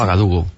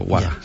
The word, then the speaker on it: Ouagadougou: ¿Cómo se dice? – Ouagadougou, Ouaga. Ellos le llaman Ouaga para que sea más corto, Ouaga.